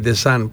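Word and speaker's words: Deschamps: Προφέρεται εν Ντεσάν.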